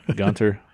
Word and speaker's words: Gunther: I don't like German. They don't pronounce the H. Gunter.